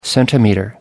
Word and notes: centimeter: British English

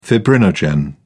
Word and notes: fibrinogen: British English

